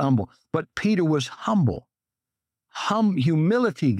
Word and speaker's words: Humble: Next thing we learn about Peter, a godly leader is humble. Has to be. In Texas, we don't pronounce the H.